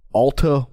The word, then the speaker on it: Alta: Cody Rigsby is taking part in Alta One.